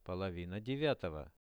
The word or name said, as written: polovina 9-ogo